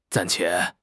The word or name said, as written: 暂且